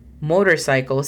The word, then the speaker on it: motorcycle: Motorcycle significa motocicleta. La pronunciación fonética es un poco extensa, pero sería la siguiente. M-O-U, mou, M-O-U-D-R-S-A-I-K-O-L. Como lo dije, es un poco extensa. Pero escucha, motorcycle.